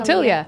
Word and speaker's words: Tillia: Tillia.